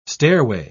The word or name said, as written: stairway